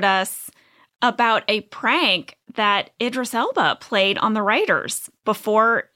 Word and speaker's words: Idris Elba: and his name is pronounced Idris Elba. – It's so ridiculous. – And they wrote it out, Idris Elba. – Yeah, they sounded it out.